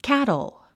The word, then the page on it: cattle: kǽtl; キャトォ